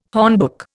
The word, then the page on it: hornbook: Indian